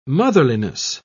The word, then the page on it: motherliness: British English